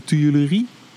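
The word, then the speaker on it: Tuileries: Ja, ik ben heel slecht in die uitspraak. Uh, le tuilerie? Hoe zeg je dat, jongens? Die, mhm. – ja. prima. Klinkt goed.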